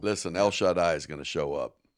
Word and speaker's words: El Shaddai: I just like the way you say it. El Shaddai. – We asked that earlier. Is it... That is, well, anyway, that's how you pronounce it.